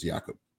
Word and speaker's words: Jakob: Jakob? – Yeah. – Yeah. Does he have any carries? I think he have like one carry. – That's how you pronounce his name, is Jakob.